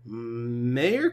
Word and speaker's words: Meyer: We didn't even talk about the possibility of Marcelo Meyer or... Meyer is how you pronounce it. My bad.